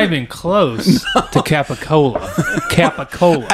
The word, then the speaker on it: capicola: I mean, it's phonetically pronounced capicola, and it's turned into gabagool.